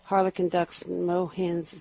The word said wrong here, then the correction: moorhens: and swans. – Moorhens and swans, right.